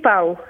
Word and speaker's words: Pauw: Vera Pauw, is it Pauw? Am I pronouncing that correctly, Vera? – It's actually Pauw.